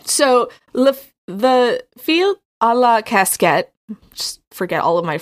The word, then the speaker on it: casquette: It's casquette, like French, but colloquially, it's spelled casket.